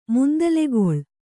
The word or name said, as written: ಮುಂದಲೆಗೊಳ್